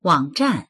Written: wang3zhan4

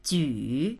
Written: ju3

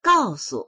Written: gao4su

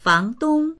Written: fang2dong1

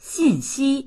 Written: xin4xi1

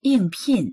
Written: ying4pin4

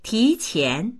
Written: ti2qian2

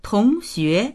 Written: tong2xue2

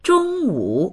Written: zhong1wu3

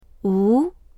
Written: wu2

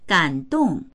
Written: gan3dong4

